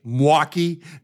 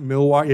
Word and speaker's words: Milwaukee: which by the way, folks, if you're from Milwaukee, you actually say Milwaukee, not Milwaukee. It's a two syllable word.